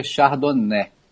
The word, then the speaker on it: Chardonnay: Então, gente, Chardonnay se pronuncia Chardonnay, francês.